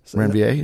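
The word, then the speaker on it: Ranvier: You talked about one of your first bands, and I always say it wrong, Nodes of Renvier. – Mm, Renvier, yeah.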